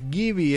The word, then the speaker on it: Gibi: Se escribe G de Guillermo, I de Indio, B corta de Víctor, Y de Yankee. Gibi se pronuncia, pero se escribe así, Gibi.